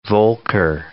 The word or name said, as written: Volcker